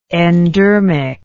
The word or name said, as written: endermically